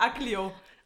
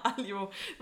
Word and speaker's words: aglio: Ich sag Aglio. Aglio. Nein, nein, nein, Alio. – Man spielt das G, weil das G spricht man ja im Italienischen, wie wir gelernt haben, oft nicht mit.